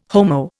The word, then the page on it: Homo: Indian